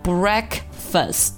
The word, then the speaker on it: breakfast: 再来看最后一个重点单词 breakfast，这个单词呢很多同学会把它读成 breakfast，注意这个单词里只有一个卷舌音啊，只有一个字母 r 出现，就在 break 这里，这个 first f-a-s-t 这里是没有字母 r 出现的，所以说不要卷舌或者带出中文的儿化音给它读成 breakfast，不要卷舌，我们就卷一次就可以了， breakfast breakfast。